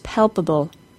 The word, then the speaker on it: palpable: Palpable has two P sounds. Korean speakers have a problem with making the P sound as an F sound. Palpable also has a B sound.